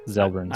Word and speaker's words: Xelbrins: His last name is X E L B R I N S. Ah. – Zelbrins.